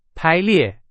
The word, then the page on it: 排列: Chinese